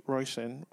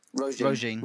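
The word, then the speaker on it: Róisín: Most of them, well, not most of them, one of them in particular, uh, Roy, Royshin, how do you say her name? – Roisin.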